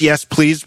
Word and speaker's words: YESPLZ: It's pronounced Yes Please, but it's spelled YESPLZ.